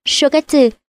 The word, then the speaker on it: Shogatsu: Ở Nhật Bản một năm có 4 dịp lễ lớn, đó là Shogatsu, đọc là Shogatsu, Hikan, đọc là Hikan và Obon, đọc là Obon.